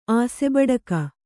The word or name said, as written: ಆಸೆಬಡಿಕ